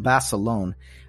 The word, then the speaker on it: Basilone: Today, we're going to be talking about a World War II veteran, John Bassilone. His last name is spelled B-A-S-I-L-O-N-E, John Bassilone.